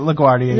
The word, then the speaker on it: LaGuardia: You say it, LaGuardia.